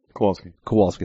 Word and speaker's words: Kowalsky: Kowalsky.